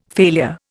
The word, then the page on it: failure: Indian